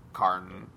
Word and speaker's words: Kohrn: Well, it's spelled K-O-H-R-N, so Karn.